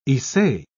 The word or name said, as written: issei